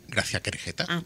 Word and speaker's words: Gracia Querejeta: Bueno, pues vamos con el siguiente estreno, que fue La Buena Suerte, una película dirigida por Gracia Querejeta. ¿Lo he dicho bien? ¿He dicho Gracia Querejeta? ¿Lo he dicho bien? – Gracia Querejeta, correctísimo.